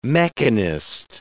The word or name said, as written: mechanist